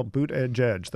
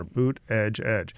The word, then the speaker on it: Buttigieg: Well, Buttigieg. That's all you have to remember. Buttigieg.